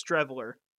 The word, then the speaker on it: Streveler: How do you pronounce that? – Chris Streveler. – Streveler.